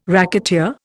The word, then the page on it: racketeer: Indian